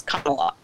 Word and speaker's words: Kamala: But I think the correct thing to do is pronounce the name that she pronounce the name her name the way she pronounces it, which is Kamala.